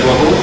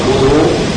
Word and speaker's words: وضوء: قال أن عثمان رضي الله عنه دعا بوضوء كما سبق في الأسبوع الماضي، أن كلمة وضوء تنطق بفتح الواو وبضم الواو، وإذا نطقت بفتح الواو معنى ذلك أن المراد به هو الماء الذي يتوضأ به، والماء الذي يتوضأ به. وإذا قيل بضم الواو وضوء معنى ذلك هو الفعل، فعل الوضوء هو فعل الوضوء.